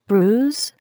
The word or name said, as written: bruise